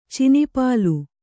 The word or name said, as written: ಚಿನಿಪಾಲು